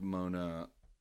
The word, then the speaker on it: Moana: M-O-N-A. – Like, oh, so Mona Lisa, not like Mona the Deftones song? M-O-A-N-A? – Exactly. – Moana? – Well, it's the Deftones song is pronounced Mona. What's the last name?